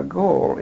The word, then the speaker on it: Galle: Now, Gaul is spelled G-A-L-L-E, and it's a fairly nondescript little town at the very southern tip of Ceylon.